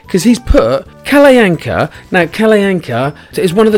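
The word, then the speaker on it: Kalle Anka: He's put Kalayanka, pronounced Kalayanka, which is how I pronounced it, because I know how to pronounce verbs.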